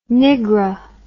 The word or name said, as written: nigra